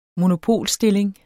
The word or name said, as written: monopolstilling